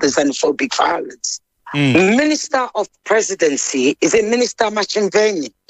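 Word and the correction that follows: Ntshavheni: Did I pronounce it right? – Nchaveni. – Did I pronounce it right? – Minister in the Presidency, Nchaveni, yes.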